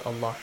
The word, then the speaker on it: Allah: Wow, we have, which has a fatha in the word Allah. The letter lam in the word Allah will be pronounced with a heavy sound.